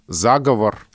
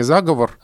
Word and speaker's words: заговор: А вот, например, а, ударение «заговор» а, закрепилось в литературном языке. Когда-то было «заговор», а стало «заговор».